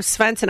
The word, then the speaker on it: Svensson: All right, Christy Svensson, owner of Svensson. I love how I do the Swedish pronunciation.